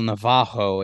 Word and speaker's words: Navarro: Uh, first of all, his name is pronounced Rafael Navajo. In all of his videos where he's been asked to speak English, he's actually referred to the rapids as rapids. I guess that's how they say it in Portuguese. R A is H A,